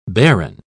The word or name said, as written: barren